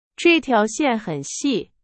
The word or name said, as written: 细